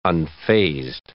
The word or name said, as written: unfazed